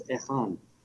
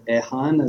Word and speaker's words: Ihan: The correct pronunciation of my name is Ihan. But I doubt if any of you will be able to do that. So you can say Ehan, as they call me in Japan, because they don't have that particular combination of vowels and consonants, kh.